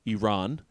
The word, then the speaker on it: Iran: I learned that's the correct pronunciation, Iran.